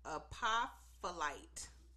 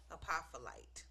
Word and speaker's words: Apophyllite: First one is going to be Apophyllite. I'm going to spell it for y'all. A-P as in Paul. O-P as in Paul. H-Y-L-L-I-T-E. Apophyllite. That's what I'm going to go with. That's what we're going with.